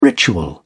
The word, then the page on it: ritual: British English